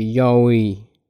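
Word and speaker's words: یاویی (弥生): کافیه دهنتون رو باز کنید و یا رو بگید و بچرخونید تو دهنتون این میشه دیگه یاویی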